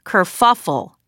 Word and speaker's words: kerfuffle: Yeah, it's kerfluffle, and it's actually kerfuffle. There isn't an L, but But I've literally been saying it wrong for, I guess, my entire life.